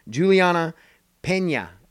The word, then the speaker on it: Julianna Peña: Uh, Jermaine Durandame uh, versus Julia Pena. Julianna Peña is how you say it.